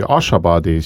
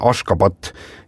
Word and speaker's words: Ashgabat: Nende pealinn, mida mina mäletan tõesti, kui Ashabadi, sina ütlesid enne mingi teise nime, sinna ka sotsite. – Jaa, selle pealinna tänapäevane õige kirjapilt ja hääldus on Ashgabat ja siis 1991.